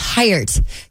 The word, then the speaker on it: Heiert: Well, and uh, if you're wondering, the last name, because we've been talking about it, is Heiert.